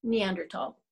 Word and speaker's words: Neandertal: Either way, whether it has an H or not, it is Neandertal.